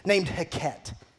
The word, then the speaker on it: Heqet: One of the false gods that the ancient Egyptians were worshiping during the time of Moses was a goddess named Heket. Heket, H-E-Q-E-T, that's how you spell it, H-E-Q-E-T, you look it up, H-E-Q-E-T.